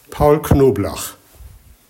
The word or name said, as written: Paul Knoblach